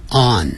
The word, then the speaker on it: on: 要用 on。很多人念成 on，你念成 on 的话就变成 ong 了，有 g 的话才会念成 on，那个鼻音出来，没有 g 啊，所以这个时候呢是 on 啊，所以不要再发，从黑龙江一直到海南岛，我们都是念成 on，不要念成这样念，念安全的安 on，有的那个外国人嘴巴更稍微圆一点 on 都可以。